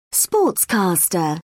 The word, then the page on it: sportscaster: British English